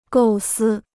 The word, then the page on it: 构思: gòu sī